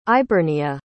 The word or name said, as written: ibernia